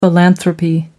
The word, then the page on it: philanthropy: British English